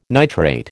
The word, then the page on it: nitrate: American English